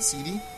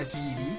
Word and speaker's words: SeeD: I found out it was actually called Seed, because it's Balm Garden. You're a seed of Balm Garden. I've been saying it wrong this whole time. Anyways, it's spelled SEE, capital D, so you read SEED.